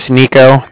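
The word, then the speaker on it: Niko: So like I said, my name's Nico. That's N-I-K-O. And then I'm calling from the Bank of Cardiff. And that's spelled C-A-R-D-I-F-F, as in Frank, Frank.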